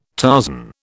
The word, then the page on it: Tarzan: British English